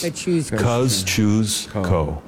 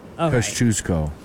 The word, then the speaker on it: Kosciuszko: First syllable is cuz. – Oh, cuz. – Like the word cousin. The second one is choose. Like choose or lose. – Kosciuszko. – Kosciuszko.